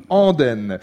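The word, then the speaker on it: Andaine: Andenne, ça s'écrit A-N-D-A-I-N-E, c'est ça. Et ça n'a rien à voir avec les Ardennes, mais c'est... – Les Andennes. – Andenne.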